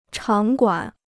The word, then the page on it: 场馆: chǎng guǎn